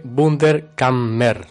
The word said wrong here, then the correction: Wunderkammer: ¿no? – Wunderkammer, exactamente.